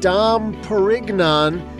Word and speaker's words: Dom Perignon: Way back in 1697, a monk named Dom Perignon, and I have it on good authority, that's the way he pronounced it, he accidentally created a whole type of drink while trying to make still wine.